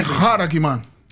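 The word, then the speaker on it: charagma: Por isso que o termo, a palavra para sinais em Apocalipse, que é semeion, e a palavra marca, que é karagma ou haragma na pronúncia do pastor Roberto, que segue pelo Serasmiano, uh, ah, que é haragma. Haragma.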